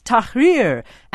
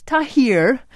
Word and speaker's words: Tahrir: Could Mr. Brooks please take a moment to learn how to pronounce Tahrir? Asks listener Michael Mueller. It's not Tahrir.